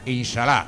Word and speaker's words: Inshallah: Hemos seleccionado un tema titulado Inshallah, no sé si lo he dicho bien. – Inshallah, vale.